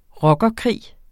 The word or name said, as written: rockerkrig